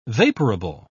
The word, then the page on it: vaporable: British English